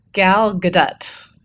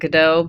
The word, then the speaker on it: Gadot: Gal Gadot. I know some people pronounce it Godot, but somehow I think Wonder Woman would fit right in with our amazing production crew that we have at Ping.